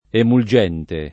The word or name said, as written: emulgente